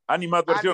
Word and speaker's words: animadversión: O sea, qué bueno que hay esa eh, inadversión, o cómo se dice, inadmer... Ani, anima, animadversión. – Animadversión, perdón, perdón, perdón.